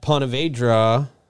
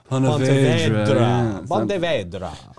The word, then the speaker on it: Ponte Vedra: At Ponte Vedra, didn't say that right. – Vedra. – Ponte Vedra.